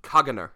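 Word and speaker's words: Caganer: Yeah, so this is the Cagnar. – Tell me about it. – I believe that's how it's pronounced.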